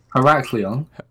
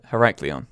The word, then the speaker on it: Heraklion: Heraklion. – Is that it? Heraklion.